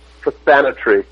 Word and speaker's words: Prothonotary: It's spelled Prothonotary, but it's actually pronounced Prothanatory.